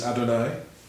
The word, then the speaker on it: Adonai: There are at least two most used names for God in Hebrew, Elohim, and what our Hebrew cousins would pronounce as Adonai, Lord.